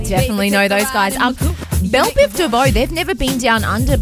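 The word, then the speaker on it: Bell Biv DeVoe: But they're also bringing some friends with them, like Belle Bivadeau. – Belle Biv DeVoe.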